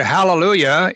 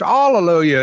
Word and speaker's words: hallelujah: Well, if you go to the Greek, there's a breathing mark there, which causes a ha to be pronounced. And so when you put a ha on hallelujah, you get hallelujah, right?